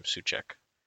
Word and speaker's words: Souček: Yeah, I think most people think Tomasz Souček, how do you say his name? Souček. – That's right.